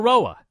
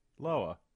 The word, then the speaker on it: Loa: Is it Loa or Roa? What is it? – It's whatever you want it to be. – It's spelled right? – One way and pronounced the other way.